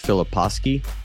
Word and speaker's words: Filipowski: It's Filipowski.